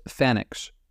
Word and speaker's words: FANX: The E-Link school was held at one of the NSA's office complexes just north of Fort Meade called the FANX, pronounced Fanix.